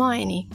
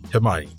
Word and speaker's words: Hermione: Hermione. Hermione.